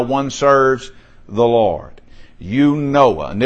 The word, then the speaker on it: Eunoia: The Greek word sounds like this. Eunoia. Eunoia. I'm just pronounce it the way it's spelled, the way it reads out.